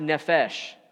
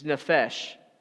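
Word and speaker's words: nefesh (נפש): And in the Hebrew, soul is pronounced nefesh. It's nefesh.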